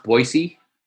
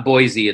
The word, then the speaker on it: Boise: It's pronounced Boise. – Idaho Falls. – It's not Boise. It's Boise.